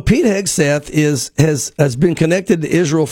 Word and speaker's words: Pete Hegseth: Pete Hegseth, is that how you pronounce it? – Yes, you're right.